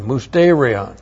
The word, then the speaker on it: musterion: Musterion, M-U-S-T, long E-R-I-O-N, Musterion.